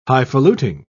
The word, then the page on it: highfaluting: British English